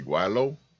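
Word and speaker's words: gweilo: Yo, I like that because now I can sit there and say guaylo. I hope that's the right pronunciation. – It is.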